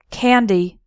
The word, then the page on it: candy: American English